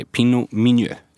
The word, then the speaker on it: Pinot Meunier: Och det är Pinot Meunier. Eller uttalar jag det rätt? – Ja, du får godkänt från mig i alla fall.